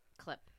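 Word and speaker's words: Kohlhepp: Todd Colehip. Todd Colehip. – Clip. – It's K-O-H-L-H-E-P-P. So it'd be like Cole. Hep. Clip.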